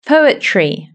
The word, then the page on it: poetry: British English